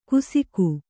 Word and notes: ಕುಸಿಕು: kusiku

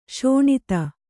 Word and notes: ಸುನೀತ: kannada

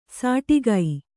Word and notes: ಸಾಟಿಗೈ: sāṭigai

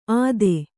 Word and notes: ಅತ್ತೆ: atte

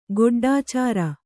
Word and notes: ಗೊಡ್ಡಾಚಾರ: goḍḍācāra

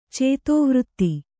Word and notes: ಚೇತೋವೃತ್ತಿ: cētōvřtti

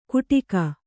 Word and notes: ಗುಡಿಕ: guḍika

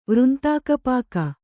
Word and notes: ವೃಂತಾಕಪಾಕ: kannada

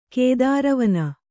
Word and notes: ಕೇದಾರವನ: kēdāra vana

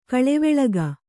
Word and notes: ಕೊಳವಾಳಿಕೆ: koḷavāḷike; Kannada

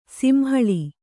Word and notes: ಸಿಂಹಳಿ: simhaḷi

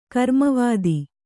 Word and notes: ಕರ್ಮವಾದ: Kannada

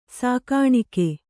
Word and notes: ಸಾಕಾಣಿಕೆ: Kannada

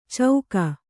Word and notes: ಚೌಕ: cauka

